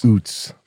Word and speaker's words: Utz: They're saying it's Uts. – It's Oots. – Uts. – It's Oots. – Well, that's spelled U-H-T-T-S. This is spelled Uts. U-T-Z.